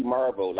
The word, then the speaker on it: Marvel: It's actually Marvel, like the comic book.